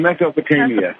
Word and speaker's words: Mesopotamia: in Mesopotamia, Batamia. How do I say that, Terry? – Oh, you said it right the first time. – I think Mesopotamia.